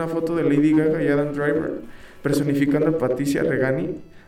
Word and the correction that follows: Reggiani: Reggiani, Reggiani, como se pronuncia, y Mauricio Gucci, güey.